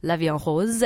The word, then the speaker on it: La Vie en Rose: We say, oh, it's rose-colored glasses, you're in love, everything looks pink, and the French take on it, of course, Edith Piaf, La Vie en Rose, and that's one of the songs, but it's a Korean take on it.